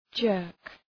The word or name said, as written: jerk